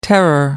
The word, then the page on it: terror: American English